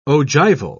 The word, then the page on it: ogival: British English